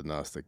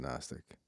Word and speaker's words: Gnostic: Gnostic Gospels, it's spelled with a G. – Yes. Yeah, you can't, this is radio, you can't hear the G, but I'm pronouncing a G. You just can't hear it. Because Gnostic with an N is not a word. Everyone knows it's Gnostic with a G. Yeah, don't remember it's a G for like, oh, G.